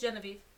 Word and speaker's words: Genevieve: We find out that one Sienna and Genevieve, I don't know how to pronounce her name. – It's Genevieve. – Genevieve, not mm-hmm. Genevieve. Genevieve. – Genevieve. – Genevieve. – Yeah. – Gen of Eve. Genevieve.